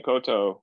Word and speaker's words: koutou: Tēnā koutou.